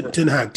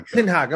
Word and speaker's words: Ten Hag: You got like Ten Hag, I can't pronounce his name, the Dutch coach. – Oh, Ten Hag. – Yeah. Ten Hag, I'm sorry, Ten Hag.